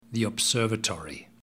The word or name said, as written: the observatory